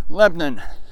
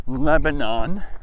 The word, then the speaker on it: Lebanon: Well, shortly after this came out, county commissioners in a county called Lebanon, which, if you look at it, would be Lebanon, but we don't pronounce it Lebanon in Pennsylvania. If you listen to our media, they will say Lebanon because they're taking time to pronounce the word the way we would pronounce it. But most people around here would just go ahead and slur it and just shorten it down to Lebanon. So instead of three syllables, Lebanon, it's two syllables, Lebanon.